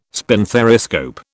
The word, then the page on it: spinthariscope: British English